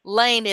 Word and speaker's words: Laine: Laine is L-A-I-N-E.